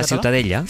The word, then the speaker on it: Ciutadella: La Ciutadella.